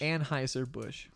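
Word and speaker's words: Anheuser-Busch: Okay, did I say that right? – Yeah. – Amsheimer Bush? – Anheuser Bush.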